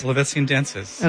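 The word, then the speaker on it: Polovtsian Dances: So that, tell me if I'm saying it right, the Polovetsian Dances? – Polovetsian Dances. – Okay, and beautiful.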